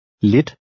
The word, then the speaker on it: Lit: In number 3, remember to pronounce the I with the short I sound because it's followed by two consonants. Try to read number 3. – Lit.